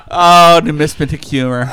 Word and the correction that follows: numismatic: Numismatic humor. I pronounced it wrong.